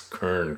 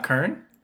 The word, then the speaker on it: Kern: I'm still working on just trying to pronounce Kern correctly. – Well, is it not Kern? – Well, it's not. It sounds different when they say it in Germany.